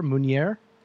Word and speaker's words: Meunier: M-E-U-N-I-E-R, Meunier? – Meunier, yeah.